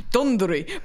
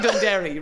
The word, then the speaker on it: Dunderry: Some people pronounce it Dundery, but it's actually Dunderry, really, if you think about it.